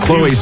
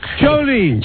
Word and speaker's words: Chloe: The name Chloe, being that it's French, I gets mispronounced. Like, I'm from Southern California, and there's a very large Hispanic community there, and it's always Chlo. – Chloe. – Chlo or Chole.